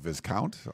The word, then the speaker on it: Viscount: And then V-I-S in front of it. How would you pronounce that? – Like Viscount? I don't know. – No, sir. Viscount. – Viscount. No S.